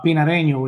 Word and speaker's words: Pinareño: the Pinorejo, Pinareno. I already forget how you how you say it. Um, tobacco. – he's talking about Pinareño,